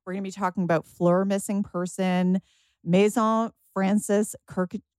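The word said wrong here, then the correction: Kurkdjian: Oh, Jill, how do you say this one? Help me out. – Maison Francis Kirk de Jan, Baccarat Rouge 540.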